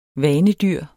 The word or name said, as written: vanedyr